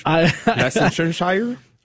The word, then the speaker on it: Leicestershire: From 2015 to 2019, he was abbot of Mount St. Bernard Abbey in um, licensure, licensure, England. I cannot pronounce anything today. Leicestershire, Worcestershire.